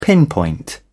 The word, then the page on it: pinpoint: British English